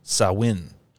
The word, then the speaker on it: Samhain: So Halloween holiday has its roots in an ancient Celtic festival of the Samhain, which is pronounced Samhain.